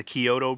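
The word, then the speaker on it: Chiodo: There's some really cheesy like special effects and stuff, but yeah, so I'm guessing it's the Kyoto brothers. Is that how you say it? – Yeah.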